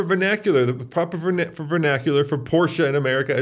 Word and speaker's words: Porsche: Because Porsche is how you say it in German, but then you say Mercedes like an American. See the problem? So you say Porsche like a German, but Mercedes.